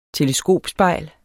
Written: teleskopspejl

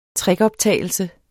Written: trickoptagelse

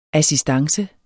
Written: ASSISTANCER

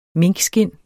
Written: minkskind

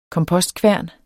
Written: kompost

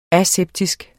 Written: aseptisk

